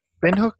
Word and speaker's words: Windhoek: Windhoek? – That sounds great.